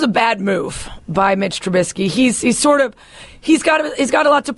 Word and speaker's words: Trubisky: Yes, how do you pronounce it? – Trubisky. – Mitchell Trubisky, yes.